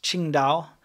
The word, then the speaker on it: Qingdao: But it's pronounced Qingdao, same as the city.